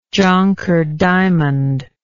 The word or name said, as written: jonker diamond